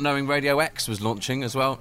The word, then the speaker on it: EXE: So it's spelt E-X-E, but it's said, of course, because it's Exeter Radio X.